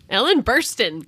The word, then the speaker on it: Ellen Burstyn: Ellen Burstyn? – Yeah. – Ellen Burstyn.